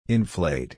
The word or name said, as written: inflate